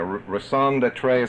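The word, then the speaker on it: raison d'être: Judas was a member of the Zealots, and their sole function, their raison d'etre, is that how you say it? It's close enough.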